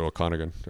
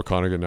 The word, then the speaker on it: Okanagan: Went in familiar with the Okanagan Valley, and the Australians pronounce it Okanagan. I'll throw that out there. That is hilarious. I just call it Okanagan now.